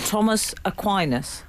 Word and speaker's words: Aquinas: And, of course, to St. Thomas Aquinas. Did I pronounce that correctly? – You did.